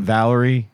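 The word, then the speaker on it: Valere: I did Valerie. Is it Valerie? It's Valerie. – It's Valerie, and I know this because they care enough that on the site they have a pronunciation guide.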